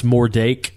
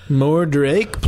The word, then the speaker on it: Mordrake: There's two different pronunciations. Some it's Mordake, and some it's Mordrake. There's a might be an extra R there. – Mordrake, please.